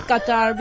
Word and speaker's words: Qatar: I say Qatar because that is the Arabic pronunciation.